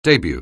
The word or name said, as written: debut